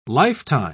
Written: lifetime

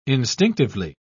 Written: instinctively

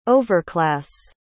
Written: overclass

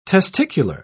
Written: testicular

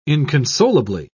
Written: inconsolably

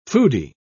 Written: foodie

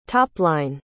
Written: topline

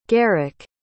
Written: gerik

